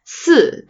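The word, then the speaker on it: si (四): And I've mentioned this on the show before, like, people don't say the number four, si, because it sounds like si, which means death.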